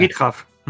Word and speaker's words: GitHub: GitHub.